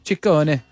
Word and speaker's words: Ciccone: Ciccone. – Ciccione. What is it, sorry? – Well, in Italian, it works the other way. So the double C's are hard C's and the singular C's are soft C's.